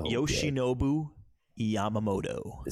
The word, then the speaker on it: Yoshinobu Yamamoto: So Yoshinobu Yamamoto.